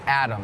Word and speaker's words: atom: Atom is tricky because it sounds like there's a D in it Atom but it's actually A-T-O-M but it's still pronounced Adam